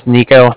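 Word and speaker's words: Niko: Again, my name's Nico. That's N-I-K-O. And the Bank of Cardiff is spelled C-A-R-D-I-F-F, as in car and then different.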